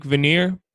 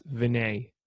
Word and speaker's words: Venier: Leanne spells her name V-E-N-I-E-R. It looks like veneer, but it's pronounced Vignier.